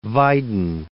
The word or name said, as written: Weyden